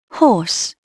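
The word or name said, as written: horse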